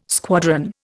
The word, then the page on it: squadron: Indian